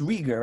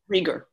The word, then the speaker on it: Rieger: But is pronounced Rieger, right? – Rieger, right. Because in Polish, it's it's a sound that's kind of in between.